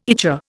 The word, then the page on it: ichor: Indian